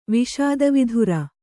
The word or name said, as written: ವಿಷಾದವಿಧುರ